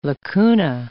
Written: lacuna

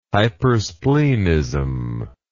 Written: hypersplenism

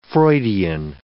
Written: Freudian

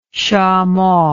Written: shamo

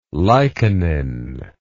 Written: lichenin

